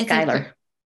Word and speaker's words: Schuyler: And in the Miranda trilogy, the first book is called Marcia Schuyler or Schuler. I'm not really sure how to pronounce it, but it's Schuyler. – It could be because it's S-C-H. Is it Schuyler? Marcia Schuyler? Okay. Maybe. Don't you hate when you read something and you don't know how to pronounce it in your head? – That's Atreides. I've always wondered how to say Atreides. Atreides. – Schuyler. That makes way more sense.